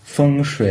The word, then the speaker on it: feng shui: the place and why is it there was a temple there, and so probably feng shui is good, and lots of lots of other elements that come into it.